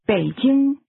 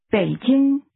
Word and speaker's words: Beijing: Beijing is pronounced with a falling, rising tone, followed by a high-level tone. Say it again. – Beijing. Beijing.